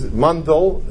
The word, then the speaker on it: Mandel: Rabbi Seth Mandel, he's Mandel is the way he pronounces his name, has been in charge of OU Meet for how long?